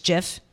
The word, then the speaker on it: GIF: But then there was a whole article a few years ago on pronouncing GIF isn't GIF, it's JIF.